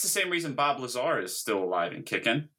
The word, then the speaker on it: Lazar: Is that how you pronounce his name? I already thought it was Lazarus. Is it Lazarus? – Lazar. – Oh, shit. – Just Lazar.